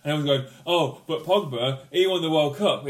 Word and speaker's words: Pogba: I tweeted something and said, Pogba's run the show. I keep saying his name wrong, sorry. Pogba. Pogba's run this show.